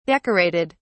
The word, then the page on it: decorated: British English